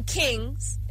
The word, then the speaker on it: Kheengz: So he goes by the name Kings, except it's spelled K-H-E-E-N-G-Z.